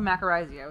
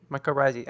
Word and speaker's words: mycorrhizae: But, oh, talking about the mycorrhizae.